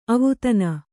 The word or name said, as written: ಅವದಾನ